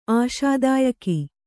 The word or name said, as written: ಆಶಾದಾಯಕಿ